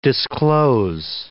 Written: disclose

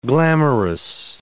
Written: glamorous

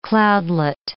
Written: cloudlet

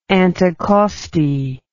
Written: anticosti

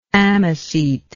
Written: ammocete

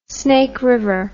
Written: snake river

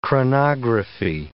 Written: chronography

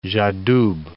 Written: j'adoube

